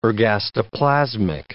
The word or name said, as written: ergastoplasmic